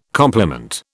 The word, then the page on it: compliment: British English